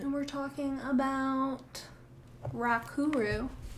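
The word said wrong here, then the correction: Ra Uru Hu: Ra-uru-hu. Butchered that, I'm sure. – Ra-uru-hu. Sounds about right.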